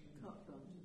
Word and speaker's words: carte blanche: There's, like you said, there's never a carte blanche, what do you call it? – Carte blanche. – Carte blanche, that's how you say it.